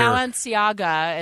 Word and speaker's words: Balenciaga: Balenciaga, as it's pronounced, makes like $2,000 purses and $1,100 tennis runners, tennis shoes.